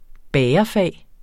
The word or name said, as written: bagerfag